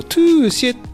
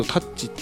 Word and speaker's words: Touché: なるんですけど、はい、えー、っとフランスのですね、えー、っとエクスプレッシブ E 社というですねあの会社から出てる、えー、っとトゥーシェっていう、まあ、ちょっと英語読みするとタッチって読むんですけど、